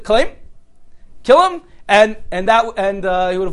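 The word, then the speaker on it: Kalim (כלם): Kalim. Chaf Lamid Mem. Okay, it's very easy to remember. It sounds like the word kill him in English, right?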